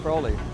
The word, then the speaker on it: Crowley: But he also wrote a whole series on Aleister Crowley. And I always said Crowley until when I edited it, you know, he put in parentheses, sounds like holy, or pronounced like holy.